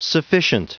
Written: sufficient

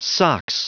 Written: sox

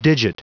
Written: digit

